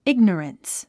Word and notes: ignorance: íɡnərəns; イグノォランス

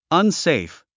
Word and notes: unsafe: ʌnséif; アンセェイフ

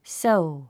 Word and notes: sow: sóu; ソウ

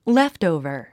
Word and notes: leftover: léftòuvə r; レフトウバァ

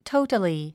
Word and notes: totally: tóutəli; トータリィ

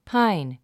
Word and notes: pine: páin; パァイン

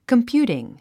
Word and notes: computing: kəmpjúːtiŋ; コンピューティン

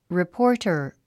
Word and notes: reporter: ripɔ’ r ːtə r; リポォター

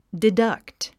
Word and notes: deduct: didʌ́kt; ディダァクト

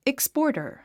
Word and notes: exporter: ékspɔ̀ːrtər; エクスポータァ

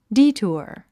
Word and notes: detour: díːtuə r; ディートゥワ

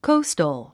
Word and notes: coastal: kóustəl; コォゥストォ

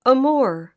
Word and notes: amour: əmúər; アムゥア

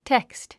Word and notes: text: tékst; テェクスト

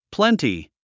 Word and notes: plenty: plénti; プレンティ

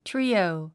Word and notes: trio: tríːou; トゥリィオゥ

